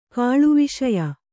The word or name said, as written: ಕಾಳುವಿಷಯ